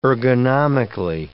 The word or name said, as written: ergonomically